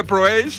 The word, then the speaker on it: Proace: Está a punto de leer que ponía: por favor, recordad que se dice tal cual se escribe Proace. Coño, pero si yo digo siempre Proace, pero que, ¿quién es el paleto que no dice? ¿Alguien que dice Proace o qué? Es Proace de toda la vida.